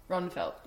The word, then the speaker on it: Ronnfeldt: Yeah, you don't pronounce the D, you did perfect. Yeah. – Okay, Ronnfeldt. – Ronnfeldt.